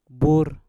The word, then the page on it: bur: Mpur